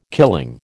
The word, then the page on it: killing: American English